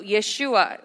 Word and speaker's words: Yeshua: So Yeshua, I know we use the sort of two different languages to say Jesus Christ in different language, but Yeshua being its original pronunciation for the name Jesus, Yeshua, and Christ being king, but Yeshua, Messiah, right?